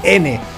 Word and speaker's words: Merzhin: Viendo en directo a los Merzin, Merzin, así lo pronunciaríamos, haciendo gala de mi afrancesado acento, Merzin,